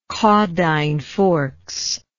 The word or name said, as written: Caudine Forks